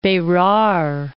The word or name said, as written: Central Provinces and Berar